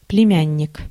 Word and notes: племянник: /plʲɪˈmʲænʲːɪk/; plemjannik

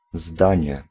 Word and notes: zdanie: [ˈzdaɲɛ]; /zdãńe/

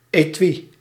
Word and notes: etui: [eˈtwi]

